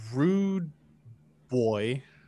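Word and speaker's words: Rude Bwoy: And I feel like this one, because he already has great instrumentals on his previous albums, like Fly Trapping of Rude Boy, if I said that right, because it's pronounced B-W-O-Y.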